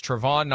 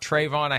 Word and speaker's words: Travon: I'm saying his first name wrong. As I learned last night, it's Travon, not Trayvon. I have to keep working on that.